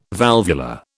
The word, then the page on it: valvular: British English